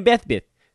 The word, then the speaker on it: Besbris: Think Bespris and then drop an R in the second half. Maybe that's the easiest way. You can just call me Dan Bespris. It's like how a child would pronounce it. B-E-S-B-R-I-S on Twitter.